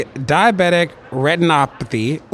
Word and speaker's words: diabetic retinopathy: You study diabetic retinopathy. Ooh, I said it right. I said it right, didn't I? – Yes, you did.